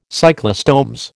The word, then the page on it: cyclostomes: American English